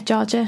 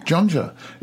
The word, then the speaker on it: Jorja: Is it pronounced Georgia? – Yeah, Georgia. How are you pronouncing it? – Jonja. It's J-O-N-J-A. – No, J-O-R-J-A. – Oh, it's an R, is it? – It's an R, Georgia.